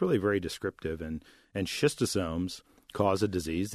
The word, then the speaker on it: schistosomes: They have, go by the name schistosomes, if I've got the correct pronunciation. – You did very well, Ben. Yeah, schistosomes,